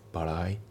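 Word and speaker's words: bad eye: これも一番下のところには書いてないですけどね、よく出る表現です、うん、で、あと発音ですけど、バッドアイのところがリンキングが起きてて、さらに T が L で濁りますね。バライだね、バッドアイじゃなくてバライだね。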